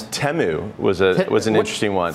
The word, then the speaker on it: Temu: And this year they pronounced it in the ad, and a lot of people were surprised that I'd been saying Temu. It's Temu, according to the ads that we saw last night.